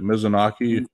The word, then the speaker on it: Mizunami: The one thing that I mentioned, you kind of already mentioned it, was the fact that Ryo Mizunami, is that how you say it? – Mizunami.